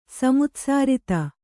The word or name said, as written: ಸಮುತ್ಸಾರಿತ